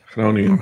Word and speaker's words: Groningen: And the pronunciation of that is Groningen. – Groningen? – Groningen, yes. – You gotta get the ch in there, almost like Hebrew.